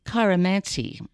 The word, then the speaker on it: Chiromancy: Chiromancy, I think, is how you pronounce it. C-H-I-R-O-M-A-N-C-Y.